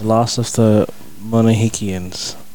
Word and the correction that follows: Mohicans: How would you pronounce it? – Mohicans. – Mohicans.